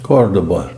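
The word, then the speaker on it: Córdoba: Not only how did it get south, but how did Jews end up in Cordova? Cordoba? How do you say it? – Cordoba. – Cordoba.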